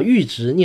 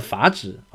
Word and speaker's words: 阈值: 这个专辑的上一集我犯了一个很低级的错误，就是把阈值念成了法值啊，